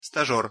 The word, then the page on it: stazer: Russian